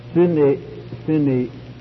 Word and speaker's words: Sinaitic: Additionally, a critical revision of the Greek text by Griesbach was published, which became the basis for many new translations, and then the Sinaitic, or if we want to really pronounce it properly, Sinaitic manuscript was discovered with a perfect New Testament and not a single leaf missing, as lettered there.